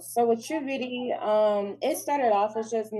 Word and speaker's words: Trubeauti: Trubeauti.